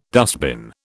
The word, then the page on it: dustbin: British English